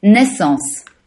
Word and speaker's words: Naissance: I don't say it correctly. Let me hear you say it one more time. – Naissance. – Naissance. Okay. – Naissance. Great.